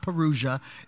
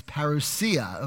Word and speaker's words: parousia: It's not Perugia, it's parousia, okay? It's spelled like Perugia, but it's not.